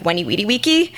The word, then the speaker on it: veni, vidi, vici: In Latin, the W's are pronounced like V's are pronounced like W's, so it's like "veni, vidi, vici."